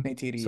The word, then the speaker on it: Neytiri: I was more expecting, you know, a return of Sully and uh Neytiri. Is that how you say her name? – Neytiri, so, yes